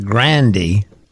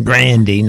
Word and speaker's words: Grande: Now, we were talking about Ariana Grande. – Which she now is telling us is the correct way to pronounce her name. – Grande, not Grande.